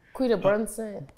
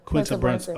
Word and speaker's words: Quinta Brunson: am I saying her name right? – Quinta Brunson. – Quinta Brunson. Okay.